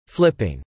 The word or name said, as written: flipping